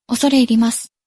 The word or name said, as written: osore irimasu